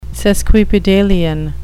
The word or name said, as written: sesquipedalian